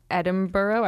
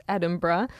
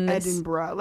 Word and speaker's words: Edinburgh: And then I got on stage and I said Edinburgh. I pronounced it wrong. It's Edinburgh. – Edinburgh.